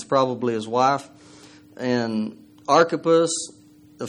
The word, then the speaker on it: Archippus: It kind of sounds like it should be Archippus, but it's Archippus is how his name is pronounced.